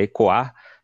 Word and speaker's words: Ekoa: É que a pronúncia correta é ecoar. Eu até também demorei para aprender, né? A gente, a gente ainda continua reproduzindo ecoa, mas né, no, entre os guaranis eles utilizam a pronúncia ecoar e significa morada.